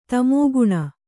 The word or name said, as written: ತಮೋಘ್ನ